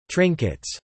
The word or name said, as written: trinkets